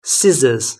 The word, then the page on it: scissors: British English